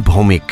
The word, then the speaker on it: Bhaumick: क्योंकि सचिन भौमिक नाम है भौमिक,